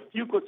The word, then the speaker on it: Fuqua: Sure, The Fuqua School of Business. – Okay, the Fuqua School of Business, and is CEO of Business Improvisations.